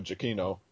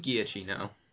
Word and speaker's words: Giacchino: And that is the Jupiter Ascending score by Michael Giacchino. – All right. That's how you pronounce his name, huh? – That's how you pronounce his name, yeah. – I always thought it was Giacchino.